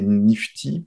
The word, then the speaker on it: NFT: donc les NFT, les Nifty, parfois on les prononce comme ça.